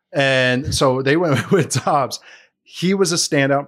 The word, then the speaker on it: Doubs: I always want to say Romeo Doubs, but apparently it's Dobbs, how you pronounce it. I've heard three different pronunciations for it. So I'm just I think it is Dobbs. They threw the U in there just to confuse everybody.